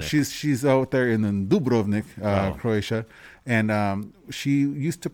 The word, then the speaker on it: Dubrovnik: So if you I noticed that when she pronounced Dubrovnik, she pronounced the accent on the first syllable. So she always Dubrovnik. Said Dubrovnik.